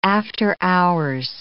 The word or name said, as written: after-hours